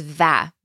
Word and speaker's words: و: So this can be pronounced either as o or as va. Both are used often. Va. But in conversation, usually it's heard as o.